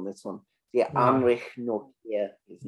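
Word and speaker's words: Nortje: Nortier, yeah. – Nortier. – TJ is pronounced like a K. – Yeah. Nortier. Yes.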